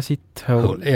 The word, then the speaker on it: Sithole: Tema nimi, kuula nüüd, on Jaja Sithole. – Jaja Sithole. – Ja. – Kuidas niimoodi hääldataksegi? – No kirjutatakse S-I-T ja hole, nagu inglis keeles on siis.